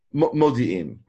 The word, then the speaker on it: Modi'in: Modi'in.